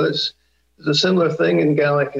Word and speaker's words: Gaelic: or was probably better known as Gaelic, but we pronounce it Gaelic here, because a lot of that language is still, it doesn't really have a lot of terms for domination either.